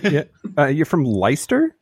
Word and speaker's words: Leicester: Leicester has the most random spelling. It catches everyone out. And I couldn't spell it at school. So, yeah, I feel vindicated that I didn't try to say the C. So, like, at the very least, I got that part right, the silent C.